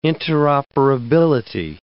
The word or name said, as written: interoperability